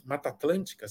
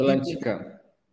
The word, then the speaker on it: Mata Atlântica: Mata Atlântica, será que eu falei certo? Serra do Japi. – Atlântica. – É, acho Mata, que é Atlântica. – Mata Atlântica, não pode ser Atlântida, né? – Não, não é.